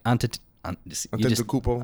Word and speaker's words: Antetokounmpo: Antetokounmpo. – Antetokounmpo.